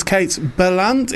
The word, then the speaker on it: Kate Berlant: That was Kate Berlant. Is that, am I saying that right? – Kate Berlant, yeah, I love her.